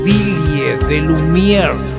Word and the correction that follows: Ville de Lumière: Ville de lumière, j'ai besoin de toi. – Bien pronunciado sería Ville de Lumière.